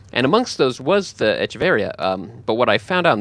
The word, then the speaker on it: Echeveria: The plant is Echeveria, which only has one R, but he is Echeveria with the two R's in the accent on the I.